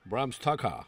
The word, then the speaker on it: Bram Stoker: Pues cuenta la leyenda que aquí viene, madre mía, qué locurita, que el propio Bram Stoker, ¿lo he dicho bien? – Bram Stoker. – Ha sonado bien, ¿sí, no? – Sí.